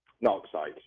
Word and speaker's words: Narcsite: Tudor, first of all, how do you say that? Is it Narsight? – Narsight, yeah.